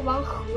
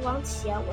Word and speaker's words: 王翦: 秦国呢，那当然就是王翦，那字有人读王和，有人读王翦，我现在采用的是“翦” 这个读音。